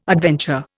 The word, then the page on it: adventure: Indian